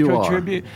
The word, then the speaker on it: Cotribute: Am I pronouncing that correctly? Is it Cotribute? I've checked twice now, so thank you.